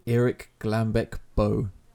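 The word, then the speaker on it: Erik Glambek Bøe: Kings of Convenience are a folk duo from Norway made up of Erland Oye and Erik Glambeck Bøe. Is that how you would have pronounced it? – Yes. Good.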